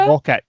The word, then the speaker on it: Mawkit: Okay, well, yes, on both counts, what is it, Mocha? Mocha? – Mocket. M-A-W-K-I-T is probably how to spell it.